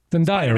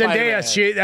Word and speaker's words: Zendaya: Zendaya, right? – No, it's Zendaya. I heard her pronounce it.